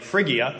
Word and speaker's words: Phrygia: P-H-R-Y. The Y was ooh in the first century. It's now like Phrygia, I guess. So Phrygia was that area that was there. The Phrygians were there at Pentecost when the gospel was preached.